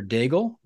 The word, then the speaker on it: Daigle: We are talking to Heather Daigle, rhymes with bagel, aka egg, from a Starbucks in 29 Palms.